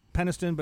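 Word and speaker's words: Penistone: Then I moved to Peniston, which is pronounced Peniston, but it's really penis tone.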